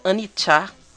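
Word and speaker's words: anicca: E a primeira música, essa música instrumental que é, se pronuncia Anicha, é é um termo do budismo que fala sobre impermanência.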